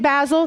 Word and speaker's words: Basil: St. Basil, that's how you say it.